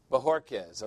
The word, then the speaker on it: Bjorquez: Say it. Bjorquez, okay. The J is an H. Bjorquez.